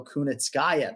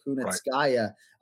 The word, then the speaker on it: Kunitskaya: And I'll give you props for pronouncing Kunitskaya because a lot of the Americans respect to you, but they want to go Kunitskaya. They always want to go Kunitskaya.